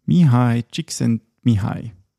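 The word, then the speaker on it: Mihály Csíkszentmihályi: How do you say it? Mihai. Chick sent me high. – Mihai Csikszentmihalyi. All right. So, Mihai Csikszentmihalyi.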